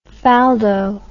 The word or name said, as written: faldo